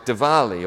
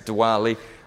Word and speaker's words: Diwali: festivals like Diwali, or you might pronounce it Diwali. I'm happy for either way.